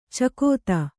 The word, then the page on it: ಚಕೋತ್ತು: cakōttu